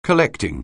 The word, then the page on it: collecting: anglais